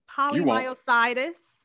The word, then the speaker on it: polymyositis: Is it polymyositis? – You were close.